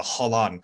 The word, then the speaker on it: Holon: Hopefully, I tried to say that correctly, the Holon. – You got it. You added the there little flip, so you nailed it.